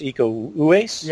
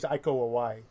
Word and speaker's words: Iko Uwais: And the guy from the Raid movies, the actor from the Raid movies, I believe his name is pronounced Iko Uwais.